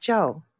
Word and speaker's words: Chou: It's just that I am a purist because I speak Chinese, so I can't bring myself to say Ruth Chou Simons when it's pronounced Zhou.